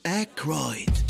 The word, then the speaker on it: Aykroyd: Aykroyd.